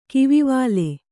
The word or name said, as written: ಕಿವಿವಾಲೆ